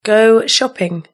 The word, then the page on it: go shopping: British English